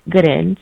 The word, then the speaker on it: Gooden: I don't use all socials, but I'm there somehow at BevT as in Tom Gooden.